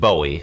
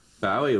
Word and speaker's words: Bowie: I always thought it was Bowie. I thought, well, it's a Scottish name, must be Bowie. But nobody in Scotland pronounces it like that. Pronounce it Bowie.